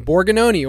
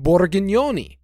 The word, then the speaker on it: Borgognoni: My last name, it's Italian, Borgognoni, or if you know, if you're pronouncing it in a more thick Italian accent, Borgognoni, okay? – Wow.